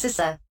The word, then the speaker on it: CISA: It is pronounced CISA.